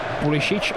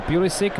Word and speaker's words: Pulisic: Ono Pulisic, těžko říct, jak jeho jméno vyslovovat, protože samozřejmě má předky v Evropě na Balkáně, proto by se člověku chtělo říkat Pulisic, ale on sám si říká Pulisic, má americkou národnost a v Americe mu neřekne nikdo jinak než Pulisic.